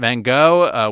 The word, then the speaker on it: Van Gogh: The other ones that we have playing now, At Eternity's Gate, it's about Vincent van Gogh. Willem Dafoe plays Van Gogh. It rhymes.